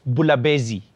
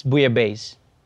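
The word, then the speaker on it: bouillabaisse: Yeah, and by the way, it's not pronounced Bulabezi. It's Bouyabaze.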